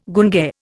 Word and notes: gunge: Indian

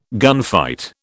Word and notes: gunfight: British English

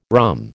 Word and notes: rhumb: American English

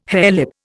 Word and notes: harelip: Indian

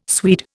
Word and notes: Swede: Indian

